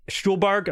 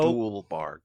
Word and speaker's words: Stuhlbarg: Stuhlbarg, I'm sorry. – Stuhlbarg.